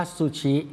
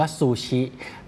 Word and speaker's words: ซูชิ: ซูชิครับคนแต่ว่าคนไทยเราเรียกว่าซูชิครับออกเสียงว่าซูชิแต่คนญี่ปุ่นออกเสียงว่าซูชิครับซูชิครับซุเสียงสั้นครับชิก็เสียงสั้น